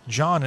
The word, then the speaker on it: Jon: John, and I don't care how you spell my name. You can spell it J-O-N, which is actually the way it is, or J-O-H-N.